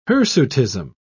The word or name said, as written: hirsutism